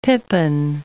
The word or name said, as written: pippin